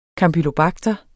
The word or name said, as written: kampylobakter